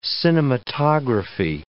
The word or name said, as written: cinematography